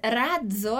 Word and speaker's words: razzo: Mas razzo, a gente tem essa palavra aqui para mostrar essa exceção, ok? Razzo, a gente vai ter a pronúncia da abelhinha. Z, z, razzo, razzo.